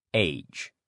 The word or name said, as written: age